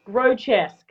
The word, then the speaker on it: Grodjesk: How do you pronounce your last name? – Grodjesk. – Grodjesk. – Yep.